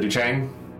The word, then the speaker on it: DuChang: I said it right. Is it Duchang? – Yes.